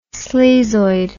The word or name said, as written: sleazoid